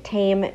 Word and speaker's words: Taim: Interesting tidbit, the word tame means tasty in Hebrew, and it's spelled T-A-I-M.